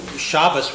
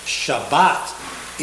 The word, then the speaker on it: Shabbat: And so Shabbos would be the language used by most European Jews in America. Shabbat is the Hebrew pronunciation.